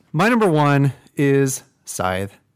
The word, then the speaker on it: Scythe: Since it's my number one, I think I have the authority to say the correct way to pronounce it, which is Scythe, not Scythe. – Yeah. – If we were to randomly poll our audience of one and ask them how to pronounce the word S-C-Y-T-H-E, I believe that they would say Scythe.